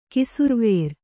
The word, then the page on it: ಕಿಸುರ್ವೇರ್: kannada